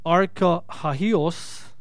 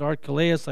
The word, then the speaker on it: Archelaus: And when he heard that, Archelaus, now it's funny because in the Greek it's pronounced Archahios, but you pronounce it Archelaus, I guess, was reigning over Judah instead of his father Herod.